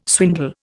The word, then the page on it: swindle: Indian